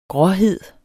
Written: gråhed

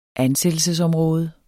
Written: ansættelsesområde

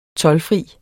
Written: toldfri

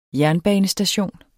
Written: jernbanestation

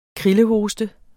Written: krillerhoste

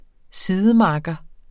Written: sidemakker

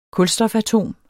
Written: kulstofatom

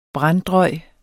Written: branddrøj

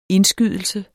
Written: indskydelse

